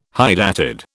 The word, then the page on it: hydatid: British English